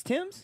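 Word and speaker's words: Tems: Oh, is that how you say her name? It's Tims? – Yeah.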